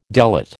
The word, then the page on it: delate: American English